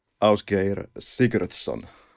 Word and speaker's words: Ásgeir Sigurðsson: Ásgeir Sigurðsson.